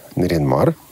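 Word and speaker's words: Нарьян-Мар: То есть вы из города Наринмар, да. – который столица Ненецкого автономного округа. Автономного округа. – Да, и ну, спасибо за ударение правильное, которое ты сделал, на первом слоге.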